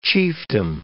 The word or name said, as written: chiefdom